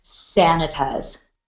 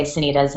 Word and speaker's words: Sanitas: I know several people who say Sanitas. And if you do research on the word, it does seem like that is likely to be the original pronunciation. Back when it was named after the sanatorium that was right there at the base of the mountain. So I think there's a lot of evidence that it was for a long time pronounced Sanitas. And now we say Sanitas, and, you know, it's just kind of too late to go back.